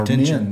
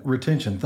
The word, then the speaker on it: retention: And the younger we figure that out, for our younger guys that are in college, seems like we're having a lot of, is it called retrition? How do you say that? We keep a lot of our college kids in town. They want to stay here. – Yeah. – Our retention. Men, retention. Thank you.